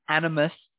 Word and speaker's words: animist: But then in the animalist, uh, did I say that right? Ab- animist